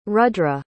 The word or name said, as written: rudra